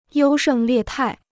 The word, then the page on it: 优胜劣汰: yōu shèng liè tài